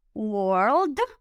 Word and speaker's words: world: Es muy importante que cuando estemos hablando en inglés siempre pronunciemos las consonantes finales como las Ds en world o old y las Ts, ¿verdad? Todas las consonantes finales se deben pronunciar.